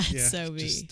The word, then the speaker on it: SoBe: Is that how you say it? – Yeah, Sobe.